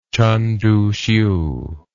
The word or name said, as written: chen tuhsiu